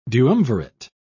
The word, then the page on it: duumvirate: British English